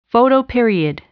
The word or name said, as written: photoperiod